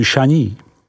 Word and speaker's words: Chagny: Côte de Beaune, vyslovko du Bon, se rozkládá od hranic obce Echevrone, vyslov Echevron, po Chagny, vyslovuj Šaní.